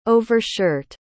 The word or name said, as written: overshirt